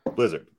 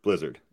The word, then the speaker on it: Blizzard: Blizzard or bl- Blizzard? Blizzard. Blizzard. Yeah. Okay. Cool.